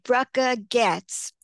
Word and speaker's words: Bracha Goetz: Well, I say it Bracha Goetz, but yeah, thank you.